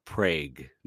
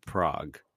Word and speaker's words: Prague: this happened in this little town called, I think they say Prague, not Prague, but it's spelled the same.